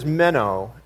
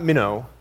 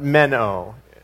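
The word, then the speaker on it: meno (μένω): In Greek, this word is meno, and it happens, not minnow, like somebody from Pennsylvania would say minnow, the little fish, but meno, M-E-N and a long O.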